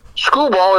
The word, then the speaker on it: Skubal: T-A-R-I-K. Skubal, his last name, S-K-U-B-A-L. Skubal. – Skubal, okay. It's like a Skubal.